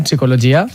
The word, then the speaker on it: psicologia: Per exemple, si anem a PAMS, en la primera, en psicologia, sense pronunciar la P, ahí no hi ha dubte.